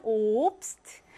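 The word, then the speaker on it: Obst: 베에스테 세 개 나오지만 옵스트. 에스테 두 개 나오지만 오스턴 이렇게 발음해야 합니다.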